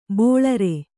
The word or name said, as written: ಬೋಳರೆ